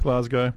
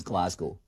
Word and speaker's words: Glasgow: So, the most common way you'll hear it pronounced is Glasgow. So you hear that it's Glas, not glass. – Glasgow. – Glas. – Glasgow. I think I'm saying it right. – It's sort of a Z.